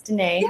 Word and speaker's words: Danae: Absolutely, yes, Danae.